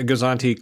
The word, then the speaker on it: Gozanti: It's a Gozanti. A Gozanti class Imperial freighter.